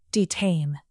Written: detain

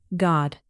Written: god

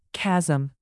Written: chasm

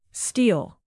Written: steel

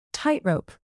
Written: tightrope

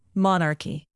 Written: monarchy

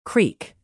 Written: creek

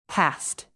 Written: past

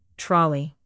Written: trolley

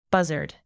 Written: buzzard

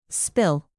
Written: spill

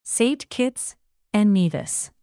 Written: saint kitts

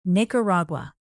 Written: nicaragua

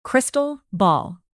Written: crystal ball

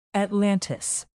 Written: atlantis

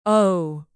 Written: ohh

